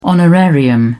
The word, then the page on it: honorarium: British English